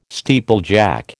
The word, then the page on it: steeplejack: American English